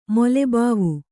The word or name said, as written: ಮೊಲೆಬಾವು